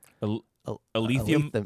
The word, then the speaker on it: alethiometer: It's called, it's spelled A-L-E-T-H-I-O-M-E-T-E-R. – Alethiometer? – Alethiometer.